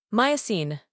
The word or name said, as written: Miocene